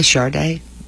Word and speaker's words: Sade: Did you just say Sade? – Yeah. S-A-D-E. That's kind of how you say it. That's how I say it.